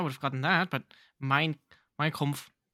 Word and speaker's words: Mein Kampf: Oh, you were saying Mein Kampf. I thought you were saying Minecraft or something. I was so confused. Please work on your pronunciation.